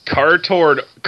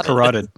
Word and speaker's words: carotid: His cartoid artery has been carotid.